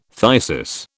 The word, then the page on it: phthisis: British English